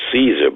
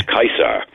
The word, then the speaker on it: Caesar: So we pronounce it Caesar, but in classical Latin, the C was a hard C, it was a K. So it was Kaiser.